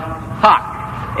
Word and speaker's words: hough: All right, the word ending in O-U-G-H that rhymes with flock. F-L-O-C-K. A word ending in O-U-G-H. Time is called hock. H-O-U-G-H. Spelled H-O-U-G-H. Pronounced H-O-C-K. Hock.